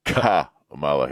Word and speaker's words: Kamala: Well, real quick, somebody said when pronouncing the cackling former vice president's name, the accent is on the first syllable. – Kamala Harris.